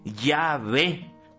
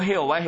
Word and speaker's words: Yahvé: J-W-H-W. Y se pronuncia científicamente Yahvé. No Jehová, Jehová está mal pronunciado.